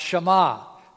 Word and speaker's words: Shema: The first word, "hear," in Hebrew is pronounced Shema, Shema Yisrael. And so this is called the Shema.